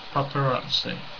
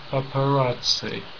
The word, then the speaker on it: paparazzi: So it's four syllables, and Nadia's saying third syllable is stressed. Okay. Papa. And it's even stressed in the song as well, paparazzi. Yeah. So she's following the correct stress pattern, which is always good.